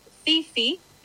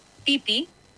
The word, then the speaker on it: Phi Phi: Seguro te referís a las islas Fifi, que en realidad se pronuncia más como Pipi, sin marcar la F.